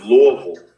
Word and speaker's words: Louisville: So it's Louisville. You have to really slur your your your uh, your L there when you do it. So there's there's not an I involved, even though ah.